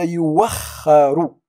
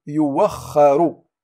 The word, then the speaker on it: يؤخر: قلنا في حالة ما قبلها ضمة، لاحظوا معا هنا قبلها ضمة يو، يو هنا قبلها ضمة تم نطقها واو، قلنا يوخر يوخر ولم نقول يؤخر، هنا كذلك ويوخركم ولا نقول ويؤخركم.